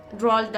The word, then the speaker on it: Roald: Roald Dahl.